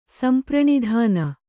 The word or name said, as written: ಸಂಪ್ರಣಿಧಾನ